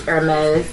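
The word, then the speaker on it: Hermès: I do, and all that logo tackiness and mixing all the Louis and incorrect pronunciations of Hermes, which is Hermes.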